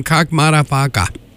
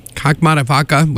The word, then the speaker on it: Kakkmaddafakka: Keeping the tunes going here with a new track from Kakmadafaka. Kakmadafaka, which is spelled K-A-K-K-M-A-D-D-A-F-A-K-K-A.